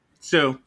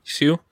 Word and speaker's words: Hsu: Oh my goodness, her last name, HSU. How do you say? – Oh yeah, Sue. – Sue.